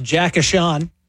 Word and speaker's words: Eichel: It's pronounced Jack Eichel.